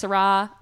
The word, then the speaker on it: Syrah: And it's Syrah, like S-Y-R-A-H. I feel like there's also Shiraz, which ends in a Z, and that's not what's in here.